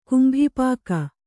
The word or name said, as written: ಕುಂಭಿಪಾಕ